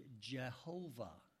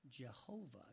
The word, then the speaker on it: Jehovah: So they took the vowels from another name for God that we call Adonai. Adonai. A-O-A. Okay, you had those three vowels. And they put those in between the four letters of the sacred name. And we came up with the word Jehovah. Jehovah. And that's how we get that pronunciation.